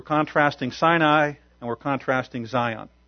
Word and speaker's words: Zion: So in historical terms, Mount Zion, or Sion as it's sometimes pronounced, is the central part of Jerusalem.